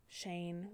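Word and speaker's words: Shayne: And then the next line, it said Shane Robinson of California.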